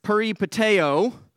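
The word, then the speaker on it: peripateo (περιπατέω): What the, the word walk here actually is a Greek word that's pronounced, let me make sure I say it right here, peripateo, and it means to tread around or to follow as a companion.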